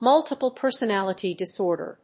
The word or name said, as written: Multiple Personality Disorder